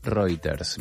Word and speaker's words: Reuters: Ahora visito la agencia de noticias Reuters o Reuters, bien pronunciada.